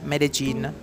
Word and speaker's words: Medellín: anzi si chiama Medellin, ecco l'ultimo giorno si imparano sempre le cose più importanti, la pronuncia esatta è Medellin.